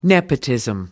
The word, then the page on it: nepotism: American English